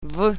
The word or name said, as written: v